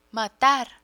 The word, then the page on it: matar: Spanish